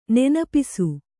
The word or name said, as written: ನೆನಪಿಸು